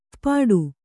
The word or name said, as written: ಪುಟ್ಟಿ